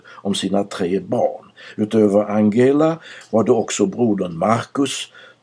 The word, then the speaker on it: Angela: De flyttade allihop några veckor efter Angela,